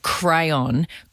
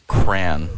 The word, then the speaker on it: crayon: C-R-A-N. Cran. – Cran. – Cran. – Cran. – You're missing a whole syllable.